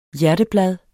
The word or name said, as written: hjerteblad